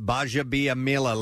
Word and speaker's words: Gbajabiamila: And that's pronounced Baja Biamila.